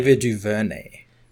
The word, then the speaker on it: DuVernay: Ava DeNevero, I can never pronounce her last name. Ava DuVernay. – DuVernay, thank you, is actually developing of all things, a Naomi show for the CW.